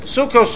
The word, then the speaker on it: Sukkos: The three times Sukkos, even though one is spelt with a Vav and the two without a Vav, but they all pronounce the same. Right? Sukkos, Sukkos, Sukkos is pronounced the same.